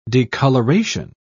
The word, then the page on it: decoloration: British English